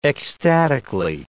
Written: ecstatically

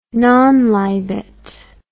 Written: non libet

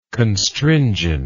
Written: constringent

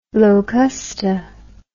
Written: locusta

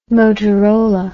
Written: motorola

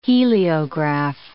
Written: heliograph

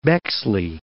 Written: Bexley

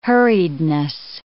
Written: hurriedness

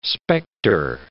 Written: specter

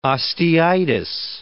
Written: osteitis

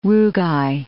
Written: rugae

